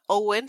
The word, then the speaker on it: Eoin: obviamente, si se llama Owen, se escribe Eoin.